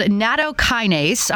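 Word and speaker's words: nattokinase: It's called Natokinase.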